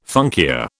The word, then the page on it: funkia: British English